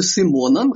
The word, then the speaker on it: Симон: Я правильно говорю «Шимона» или «Симона» нужно говорить? – Это очень интересный вопрос, многие мне задают. Дело в том, что он был Симоном, родился Симоном. И когда уехал из России, он с большим удовольствием обнаружил, что венгерская версия этого имени Шимон совпадает с еврейским.